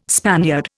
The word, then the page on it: Spaniard: Indian